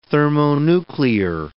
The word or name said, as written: thermonuclear